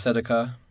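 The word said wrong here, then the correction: tzedakah: A slight correction. The correct pronunciation is Sedaka. Sedaka.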